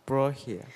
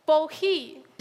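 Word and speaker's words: Bohea: 有没有同学想试试这个单词怎么念？Borki。 – 哦，Borki 是不是这意思？啊，已经很接近了。老师为你揭晓答案啊，它确实是波字开头的，后面有一点点小小的失误啊。这个单词念。 Bohi 是中文武夷二字的闽南语发音音译过来的。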